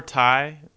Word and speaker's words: Tai: Poor Ty, but it's spelled T-A-I.